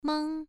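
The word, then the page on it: mang: tone 1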